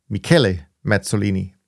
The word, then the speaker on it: Michele Mazzolini: I said Michelle because that's what it looked like to a dyslexic, but he is a he, and therefore I should have pronounced it differently. So I hope I've corrected it now by naming him Michele Mazzolini.